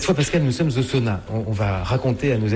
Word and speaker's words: sauna: En finnois, bien sûr, on appelle ça un sauna. – Donc c'est maintenant, on prononce toutes les lettres.